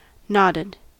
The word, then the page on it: nodded: American English